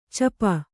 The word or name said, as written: ಚಪ